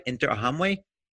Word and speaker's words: Interahamwe: So every time they came, it was the Interahamwe. Did I say it right? Interahamwe? – Yeah.